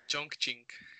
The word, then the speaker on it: Chongqing: Chongqing.